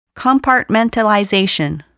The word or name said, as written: compartmentalization